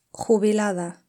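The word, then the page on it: jubilada: Spanish (Spain)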